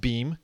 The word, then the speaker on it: Beme: Beam, spelled B-E-M-E, pronounced Beam.